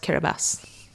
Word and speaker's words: Kiribati: K-I-R-A-B-A-T-I. – Yeah, it's eight letters. – How what? Kiribati. – That's how it's spelled. It's spelled Kiribati, but it's pronounced Kiribati.